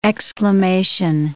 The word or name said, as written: exclamation